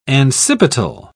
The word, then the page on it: ancipital: British English